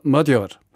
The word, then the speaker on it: Magyar: Gelernt haben wir seitdem, dass man manchmal auch ein G wie ein D ausspricht. Denn er schreibt sich ja Magyar, spricht sich aber Magyar. Versprochen hat er nicht weniger als die Wiederherstellung von Rechtsstaat und Demokratie in Ungarn.